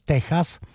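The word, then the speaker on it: Tejas: Texas, Texas by se dalo i vyslovovat, jo, ale po španělsku, že, Texas, to je pátý album kapely ZZ Top, který vyšlo v roce 1977.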